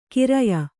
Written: ಖರೆಯ

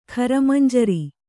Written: ಖರಮಂಜರಿ

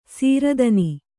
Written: ಸಿರಿದಿನ

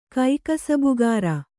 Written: ಕೈಕಸಬುಗಾರ

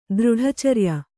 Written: ದೃಢಚರ್ಯ

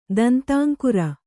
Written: ದಂತಾಂಕುರ

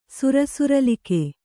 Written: ಸುರಸುರಲಿಕೆ

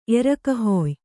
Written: ಎರಕಹೊಯ್

